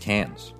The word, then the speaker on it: Cairns: And so the city you fly into out of Sydney is you go to Cairns, which is spelled C-A-I-R-N-S. – That's how it's pronounced? – Wow. – Yeah, it's pronounced Cairns. – Wow. – I'm sure some Australian's going to be like, it's Cairns.